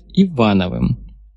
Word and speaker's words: Ивановым: «Моя партийная кличка — агосфер, но сейчас меня чаще называют Ивановым». Ударение на первом слоге, по-офицерски.